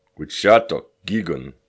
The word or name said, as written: Wucshátuk kikun!